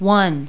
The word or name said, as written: one